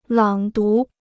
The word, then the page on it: 朗读: lǎng dú